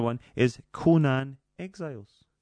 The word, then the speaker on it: Conan: Conan the Barbarian. – That's perfect. That's exactly it. It's the first time you've ever pronounced it correctly.